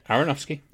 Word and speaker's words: Aronofsky: Yes, and the What's in the Box was the 2008 Darren Aronofsky. Is that how you pronounce it? Aronofsky? – Yeah, Aronofsky.